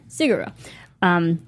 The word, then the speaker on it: Sigur Rós: Sigur Rós. – Sigur Rós. – Yeah, Sigur Rós.